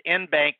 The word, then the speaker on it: en banc: It's a en banc. So anyway, it's en banc is how I pronounce it.